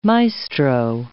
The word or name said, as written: maestro